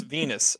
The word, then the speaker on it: Venus: Yeah, it does look like it could be pronounced Venice because it has a U.S. – But it's Venus.